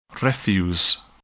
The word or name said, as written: refuse